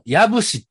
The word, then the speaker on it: 養父: 兵庫県養父市って呼んだんですけど、はい。 – 矢部市って読むらしいです。